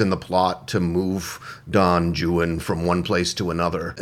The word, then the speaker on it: Don Juan: It's Don Juan, but it's pronounced Don Juan in British English.